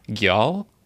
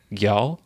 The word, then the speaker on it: Geal: I believe it is pronounced Gyal. So it is G-E-A-L, Gyal, I believe.